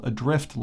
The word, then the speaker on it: ADR1FT: In 2016, a game called Adrift launched. That's spelled ADR1FT.